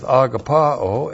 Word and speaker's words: agapao: And the word he uses there is agapao, and you're all in. You understand exactly what is being said here with agapao, A-G-A-P-A, long O.